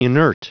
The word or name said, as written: inert